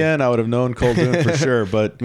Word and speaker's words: Koldun: It's pronounced Koldun. – Koldun. Yeah, sorry about that. – It's Russian for sorcerer.